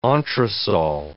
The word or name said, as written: entresol